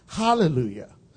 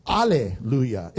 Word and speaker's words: alleluia: First of all, some of your translations may say hallelujah, but the actual pronunciation for us is alleluia.